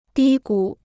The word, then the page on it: 低谷: dī gǔ